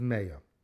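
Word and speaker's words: Meya (מאה): We get it because we don't pronounce it as Ma, but rather as Meya.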